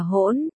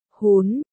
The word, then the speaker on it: hỗn: Trộn, hôn, trộn, Hán Việt là hỗn, cũng phát âm là, hún, không cần, không cần, em không ăn cái này.